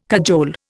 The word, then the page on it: cajole: Indian